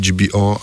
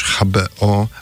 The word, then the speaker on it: HBO: W Polsce zazwyczaj mówi się UPC, tak, i HBO, ale też HBO, a jak jeśli powiem UPC, to też będzie poprawnie.